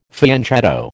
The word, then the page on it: fianchetto: American English